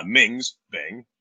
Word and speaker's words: Ming's Bing: It's called a Ming's Bing, rhymes with my name, catchy.